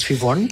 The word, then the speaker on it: Sheevon: Und Chivon, S-H-E-E-V-O-N. – Ganz wichtig, ein irischer Mädchenname, der aber in Irland anders geschrieben wird, damit die Menschen hier den aussprechen können. – Ah, okay. Was bedeutet Chivon? – Chivon bedeutet übersetzt blonde Fee.